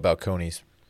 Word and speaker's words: Balcones: Call it Balcones, but it's okay. – I didn't know that. – It's pronounced that's, balcony.